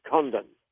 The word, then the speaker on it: Condon: This is a report, big report, carried out by the very, very skeptical Dr. Edward Condon. I'll pronounce that again very carefully, Condon.